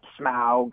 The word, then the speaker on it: Smaug: And I have to say it like that, Smaug. – Smaug.